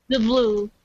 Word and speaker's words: DeBlois: It's DeBlue, not DeBlois, whatever I said. So I'm sorry. – That happens to the best of us. – I don't claim to know French or speak French or be very good at pronouncing French. So. DeBlue.